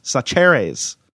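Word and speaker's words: Chachere's: The seasoning Mark mentioned is Tony Sacheres. Sacheres. And she has it pronounced phonetically here. And I still messed up.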